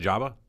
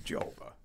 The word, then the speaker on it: Joba: Are you sure it's not Jabba? – No, Joba. – Joba. – Joba Chamber. – Vowel after the consonant would make a long O sound, am I right? – I don't know.